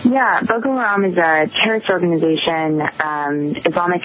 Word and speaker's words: Boko Haram: People have been mispronouncing it, called Boko Haram, kind of like the old singing group, Procol Harum. That's not the way to pronounce the name.